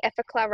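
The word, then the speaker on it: Effaclar: but I've got the Effacla range.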